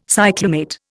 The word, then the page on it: cyclamate: Indian